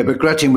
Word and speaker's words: Bagration: Yeah, so this is something that's not, I think it's pronounced Bagration. – Bagration.